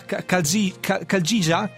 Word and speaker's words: Chalgia: E poi Mariola, scusami ancora per la pronuncia, c'è la Kaljija? Ok, perfetto.